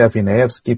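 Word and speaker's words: Afineevsky: Se escribe Afinayevsky, pero se pronuncia Afinayevsky.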